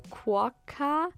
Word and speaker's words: Quokka: Wie werden die ausgesprochen? Q-U-O-K-K-A. English. Quokka. Also wahrscheinlich im Deutschen Quarkas. Deutsch Quokka. Okay, okay.